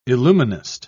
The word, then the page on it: illuminist: British English